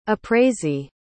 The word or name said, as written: appraisee